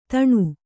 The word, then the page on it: ಧನ: Kannada